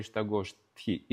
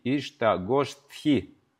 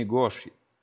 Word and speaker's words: Иштагоштхи: Дорогие преданные, произносите правильно слово Иштагоштхи. Иштагоштхи. Гоштхи, не Гоши. Не Гоши. Не Гоши. Гоштхи. Ишта.